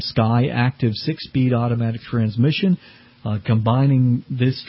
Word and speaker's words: SKYACTIV: It's called the Sky Active 4, and it's spelled just as it sounds, SKYACTIV, four-cylinder engine.